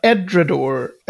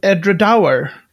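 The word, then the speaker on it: Edradour: Jag hade nog trott att det skulle heta Edredor eller så, men det, det uttalas Edredour.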